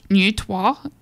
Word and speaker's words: Neutrois: Now, it's commonly pronounced Nutois, and it's spelled N-E-U-T-R-O-I-S. So, Nutois, French.